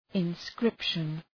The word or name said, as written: inscription